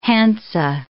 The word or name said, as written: Hansa